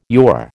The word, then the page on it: yore: American English